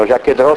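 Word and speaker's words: Jaquet-Droz: Di tutti questi automi qua ne sono rimasti, che io sappia, funzionanti più o meno tre degli Jacques Hédreau, che stanno, Jacques Hédreau si scrive, lo so che il francese non vi è, la Z finale non si legge, Jacques Hédreau.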